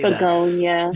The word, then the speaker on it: Bugonia: But another one that fits into that category, and help me on the pronunciation, is it Bugonia? Bugonia? I'm not sure how you say Bugonia. – That. Bugonia. Yeah. Yeah.